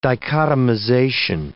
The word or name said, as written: dichotomization